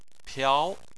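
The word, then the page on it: piao: tone 2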